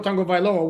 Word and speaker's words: Tagovailoa: It's the same with Tua Tagovailoa. Where's the N? There's no N. – Tagovailoa. – Yeah, it reads Tagovailoa, you know? – All right.